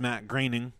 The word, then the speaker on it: Groening: it's pronounced Matt Graining. Fuck Matt Graining. Dude, I told Josh that last night, and he's like, "That's really how it's pronounced."